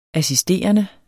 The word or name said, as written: assisterende